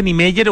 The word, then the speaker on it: Niemeyer: Yo creo que se pronuncia Niemeyer, ¿no es cierto? El apellido de este gran arquitecto brasileño. Sí, generalmente se le dice Niemeyer o Niemeyer, pero Oscar Niemeyer, un tremendo arquitecto.